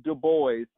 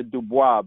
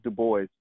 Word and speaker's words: Du Bois: Du Bois, properly pronounced Du Bois. Some people try to get French with it with the Du Bois, but he he stated clearly it was Du Bois, and uh, and also uh, Booker T.